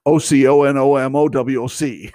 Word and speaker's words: Oconomowoc: Um, and if you see Oconomowoc, you'll know that that's not the way you pronounce it. O C O N O M O W O C.